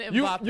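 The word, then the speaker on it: Mbappé: Killian Mbappe.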